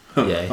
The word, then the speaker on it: Ye: Max is a big fan of Yee. A.K.A. Yeezy. A.K.A. Kanye West. – I think it's pronounced Ye. – Yay. Oh, sorry. Yay.